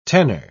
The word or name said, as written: tenner